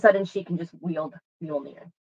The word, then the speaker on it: Mjolnir: Mjolnir. – Mjolnir.